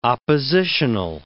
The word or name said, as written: oppositional